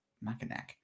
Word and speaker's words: Mackinac: Mackinac Island Race? – Mackinac.